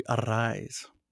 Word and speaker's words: Arraez: He does the rolling of the R's with a double R. So when they announce him, they say Louis Arraez. So that's the right way to pronounce his name.